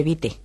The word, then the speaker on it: vite: Vit se pronuncia exactamente como si no existiera la E final. – Sin embargo, escribe vite. – Se escribe vite y la E que está por ahí al final hace que se pronuncie la T. – Claro, por eso se dice vit.